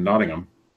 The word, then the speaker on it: Nottingham: There's a 30-year-old counselor from Nottingham.